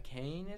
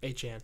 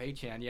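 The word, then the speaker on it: Achane: How do you say it? Akane, is it? The running back of Miami? – Achan. – Oh, that's way off. Achan, yeah, he's on IR, knee injury.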